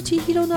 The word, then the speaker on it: 千尋: いや特に私はなぜかっていうと、同じ千尋なもんで、同じ千尋なんだけれども、でも青木さんの場合の千尋のアクセントは千尋なんだそうです。